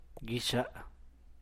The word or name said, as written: bti’ux gel